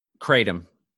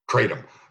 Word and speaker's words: kratom: Did I say that right? Cradium? – Kratom. – Kratom.